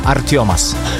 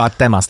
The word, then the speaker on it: Artemas: Artiomas. – Wow, naprawdę. – No, tak się wymawia, wiesz, a ty byś powiedział Artemas, tak? – Tam powiedział, no.